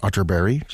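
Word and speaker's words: Atterberg: It's spelled Otterberg, but pronounced Otterberg, just in case you were Googling.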